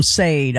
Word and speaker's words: Sade: Or that's a singer, yeah, Sade, man. Yeah, exactly. Yeah, I said it wrong on live on radio. I called him Sade. I'm like, oh, I never lived that one down. So, yeah, Sade.